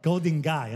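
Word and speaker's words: Golden Gai: Golden Gate. – It's okay. Called Golden Guy. Okay. I guess you pronounce it G-A-I, right?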